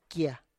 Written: kie

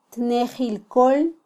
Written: tnejil kol

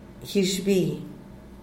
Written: guizhbi

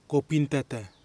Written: kopintata

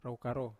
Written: rau karo

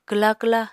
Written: kwla kwla

